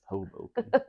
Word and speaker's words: Hoboken: Hoboken? – It's pronounced Hoboken.